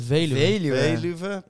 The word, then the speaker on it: Veluwe: Veluwe.